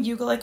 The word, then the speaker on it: Ugolik: It's Kaitlin Ugolik Phillips. Middle name cool. is spelled U-G-O-L-I-K.